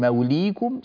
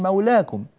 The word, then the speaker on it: مولاكم: فنحن ننطق بالألف الصغيرة ولا ننطق بهذه الياء، لأن هذه الياء أبدلت إلى ألف، بدل من موليكم صارت مولاكم، فهي تكتب بالياء لكنها تنطق بالألف.